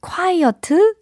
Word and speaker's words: quiet: quiet 이게 아니라 quiet, quiet, quiet. 네, 이 단어입니다. quiet.